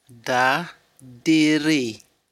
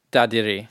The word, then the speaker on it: Dadirri: how is the word supposed to be said? – Da diri. – Da diri. – Yeah, da diri.